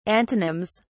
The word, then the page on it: antonyms: British English